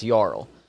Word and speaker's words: jarl: when I saw Jarl in like Norse, that's Jarl. So I was like, yeah, well, that is how you actually pronounce it.